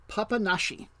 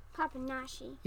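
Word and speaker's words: Papanași: That's the capital, and this you pronounce this like an sh. This is Papanashi. You say that one? – Papanashi.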